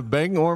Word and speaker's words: Bangor: Bangor, Maine, that's how the locals say it.